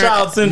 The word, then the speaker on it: Munchausen: Because Munster Jim Jim, how do you say that? Munston Jim Syndrome. You know where the parent. Munchausen Syndrome.